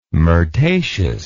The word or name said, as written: myrtaceous